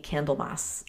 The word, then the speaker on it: Candlemas: I've heard it pronounced a couple different ways, but I think Candlemas is the best one.